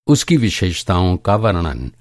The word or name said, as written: वर्णन